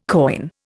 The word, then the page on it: koine: Indian